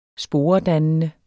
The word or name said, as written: sporedannende